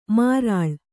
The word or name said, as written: ಮಾರಾಳ್